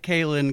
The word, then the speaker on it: Kalen: I just like Kalen, because the other ones sound too close to the male name Colin. – Okay, so Kalen it is.